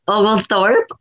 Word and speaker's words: Oglethorpe: Oglethorpe.